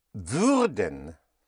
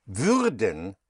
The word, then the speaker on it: würden: You have würden. Werden is will. Würden. Spelled W-U-Umlaut-R-D-E-N. Instead of werden, if you make it würden, you give it an Ü sound.